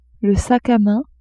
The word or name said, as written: sac à main